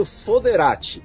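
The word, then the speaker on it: foederati: A pronúncia em latim, ela acaba sendo foderati.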